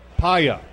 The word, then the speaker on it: Paea: I know, but a pronunciation chart. – P-A-E-A? – Paya. – Paya from Oregon State.